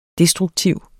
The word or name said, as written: destruktiv